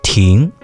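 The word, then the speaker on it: ting (亭): In the Chinese language, the pavilion is called ting, which shares the same pronunciation with another character meaning stop.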